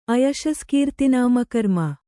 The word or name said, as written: ಅಯಶಸ್ಕೀರ್ತಿನಾಮಕರ್ಮ